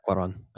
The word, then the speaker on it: Cuarón: So we'll kick it off with my pick, which is A Little Princess from 1995, directed by Alfonso Cuarón. Is that how you say his last name? – Yeah, Cuarón.